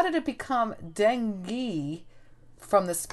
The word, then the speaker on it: dengue: I got it. It's dengue.